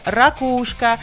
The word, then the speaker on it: ракушка: Доступны, в общем-то, оба варианта. Ударение как «ракушка», так и «ракушка». Да, на второй слог. Большинство словарей как бы уверяют, что это равноправные ударения, но, однако, предпочтительным считается ударение на втором слоге «ракушка».